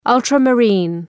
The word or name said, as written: ultramarine